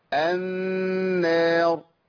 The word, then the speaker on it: النار: اما اقول النار بنطقها بقول ايه؟ بقول النار ولا بقول النار؟ صح؟ يبقى بدخل اللام في النون، بقت ايه؟ النار.